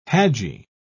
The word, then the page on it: haji: British English